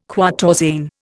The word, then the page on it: quatorzain: Indian